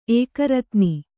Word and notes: ಏಕರತ್ನಿ: Kannada

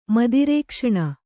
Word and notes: ಮದಿರೇಕ್ಷಣೆ: kannada

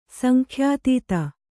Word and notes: ಸಂಖ್ಯಾತೀತ: sankhyātīta

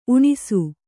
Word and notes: ಊಣಿಸು: Kannada

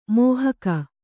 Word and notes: ಮಹಿಕೆ: mahike

